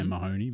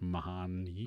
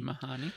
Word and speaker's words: Mahoney: I want to say Mahoney, but it's pronounced Mahoney. – Mahoney.